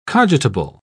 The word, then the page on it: cogitable: British English